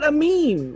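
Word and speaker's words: Aminé: How does he say he pronounce his name? – I think it's Amine. – That nigga on his first mixtape did not say that. Well, he literally said Amine.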